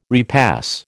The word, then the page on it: repass: American English